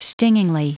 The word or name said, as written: stingingly